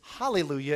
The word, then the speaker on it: hallelujah: But then when they transliterate it, kind of just smush that into Greek, they do alleluia. So when you sing a song that says alleluia, they're kind of basing it off the Greek pronunciation. If it's hallelujah, it's kind of based off the Hebrew.